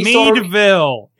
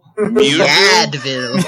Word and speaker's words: Meadville: Meadville. It's Meadville, you asshole. – Muadville. – Muadville. – Is that Meadville? – Okay, whatever. – Meadville, PA, you fuck. – At the Meadville. – I'm sorry, I'm from the area. – You fuck. – You remember those notebooks, Mead notebooks? That's how you pronounce it.